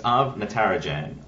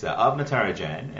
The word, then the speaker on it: Arv Natarajan: It's Arv Natarajan, although it's an Indian name. I say it with an Australian accent, so I'm probably butchering it myself if I go talk to my family.